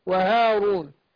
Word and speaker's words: وهارون: ايوه، هي الالف هنا طبعا هي مرسومه من غير الف، لكن في الف صغيره كده دي من عمل لجان المصاحف، هي بتنطق كده وهارون.